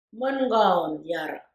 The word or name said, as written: Mengawoon yarre